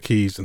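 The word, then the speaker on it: Caius: which is actually because there's a college in Cambridge where I'm from called Gonville and Keys, and it's spelled Gonville and Gaius.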